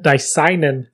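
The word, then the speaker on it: didymium: You just said it wrong. – What was it again? – Dicenin. – Dicenin glass.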